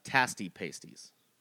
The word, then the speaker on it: Pasties: It's pronounced Tasty Pasties.